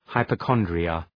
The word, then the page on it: hypochondria: {,haıpə’kɒndrıə}